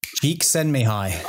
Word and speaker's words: Csikszentmihalyi: Csikszentmihalyi. – Oh, very good. Very good. – You can't twist me. I got it. Mihai Csikszentmihalyi.